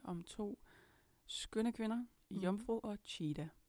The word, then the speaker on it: Iomfro: Og øh, hendes kunstnernavn, det udtales jo Jomfru, men det staves I-O-M-F-R-O.